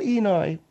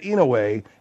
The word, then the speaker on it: Inouye: His name is Daniel Inouye. – Okay, I apologize if I mispronounced it, but through that whole show, through the entire drunk history that I did, I was pronouncing it Inouye, and nobody corrected me.